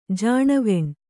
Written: ಜಾಣವೆಣ್

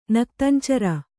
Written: ನಕ್ತಂಚರ

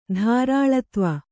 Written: ಧಾರಾಳತ್ವ

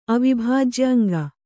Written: ಅವಿಭಾಜ್ಯಅಂಗ

